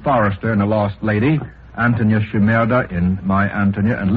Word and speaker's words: Antonia: My Antonia, that's all I know. – Yes. I think the name is pronounced Antonia.